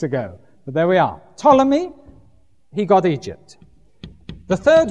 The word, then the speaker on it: Ptolemy: The second one was Ptolemy, spelt with a P and a T. P-T-O-L-E-M-Y.